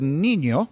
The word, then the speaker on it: niño: A tilde is that little squiggly line that they use in Spanish, at least in my knowledge, to make an N sound like a nya. So if you've ever heard the word niño, which is the Spanish word, and I speak Spanish, a niño is a boy in Spanish, and it's spelled N-I-N-O, but the second N has what's called a tilde over it because it changes the pronunciation.